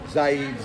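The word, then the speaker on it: Zayid: Zaid, Z is in zebra, A-Y-I-D.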